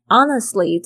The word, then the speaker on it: honest: I want to honestly， honestly 这个字大家稍微注意一下，这个 honest 它的拼法是 H-O-N-E-S-T，这个 H 没有发音哦， honest， honest，这边 honestly 这边特别注意一下 T-L-Y 这个发音呢，有时候 T 呢通常会发不太出来。